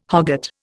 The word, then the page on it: hogget: Indian